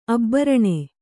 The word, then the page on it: ಅಬ್ಬರಣೆ: abbaraṇe